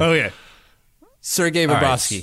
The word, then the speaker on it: Sergei: Sergei. You got to roll that R.